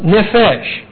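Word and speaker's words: nephesh (נֶפֶשׁ): These are little E's, short E's, hence pronounced nephesh.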